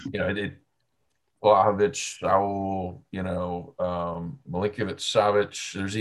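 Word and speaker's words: Vlahović: Vlahovic.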